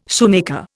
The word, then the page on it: shoemaker: Indian